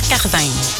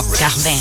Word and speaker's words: Cardin: Pantalón Pierre Cardin. Y lo dije bien en francés, ¿eh? Me lo aprendí. – Pierre Cardin. – Acuérdense que la I no se dice I, Cardin, no. – Cardin.